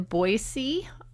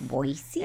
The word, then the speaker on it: Boise: I have a show in Boise on March 25th. – Boise? – And then, well, that's how you pronounce it. People always say Boise. But if you're a local, or if you've spent a lot of time down there, yeah, or up there, or over there, wherever you live, it's Boise.